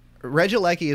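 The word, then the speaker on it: Regieleki: Now, how am I supposed, like, this is the first time I'm gonna pronounce them, so it's Regilecki, Regilicki, Regilecki? – Regilecki is what I would say.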